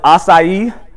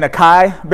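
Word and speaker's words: açaí: I remember when, I guess the way you pronounce this is acai. We're all saying acai berries, but I guess it's acai berries and acai bowls and acai juice first came on the scene.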